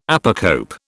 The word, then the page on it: apocope: British English